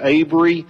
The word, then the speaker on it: Avery: And I have been told that Avery is pronounced with a long A, and I will do my best to remember Avery Argo and pronounce it the way I should.